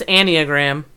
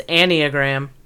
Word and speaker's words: Enneagram: It's Enneagram. – What's that? – Can you hear me? – Yeah. – It's Enneagram. – Enneagram.